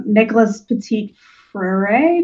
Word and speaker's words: Nicholas Petit-Frere: Nicholas Petit-Frere, did I say that right? – Yep.